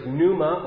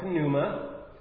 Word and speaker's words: pneuma: The Hebrew word for spirit is the word ruach, and the Greek word is pneuma or pneuma, depending on if you pronounce the P sound at the beginning of the word.